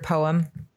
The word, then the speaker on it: poem: And every single time I say the word poem, I make sure I say it with two syllables because I'm like traumatized by that.